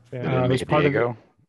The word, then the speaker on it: Diogo: A new character is Diogo. Is that how you say his name? Diogo? – Diogo. – It's D-I-O-G-O, which I didn't even know what the hell his name was.